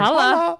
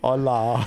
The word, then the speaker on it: hola: I'm pretty sure the H is silent, so you've been running around Spain going, holla, holla. – It's actually hola.